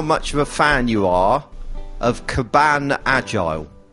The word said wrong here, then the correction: Kanban: It's not pronounced that way, is it? – Kanban. – Kaban. That's right, isn't it? – No. – Is that not right? Have I got that wrong? – Yeah, no, it's wrong. Kanban.